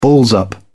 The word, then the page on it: balls up: British English